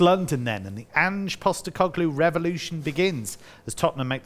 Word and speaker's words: Postecoglou: Come Postecoglou. There, there you go. You did um, it first time.